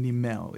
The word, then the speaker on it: Niemel: Well, my name is Leroy Niemel. I live Niemel. – In Niemel, it is. – Okay. – Niemel is the Dutch pronunciation.